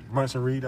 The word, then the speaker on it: Bronson Reed: And this is my first time seeing Bronson Reed.